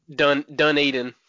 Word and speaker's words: Dunedin: Dunedin, Florida, or something like that? – Dunedin.